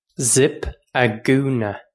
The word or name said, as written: zip a gúna